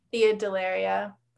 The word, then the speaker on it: Thea Dellaria: thea dellaria uh, t-h-e-a-d-e-l-l-a-r-i-a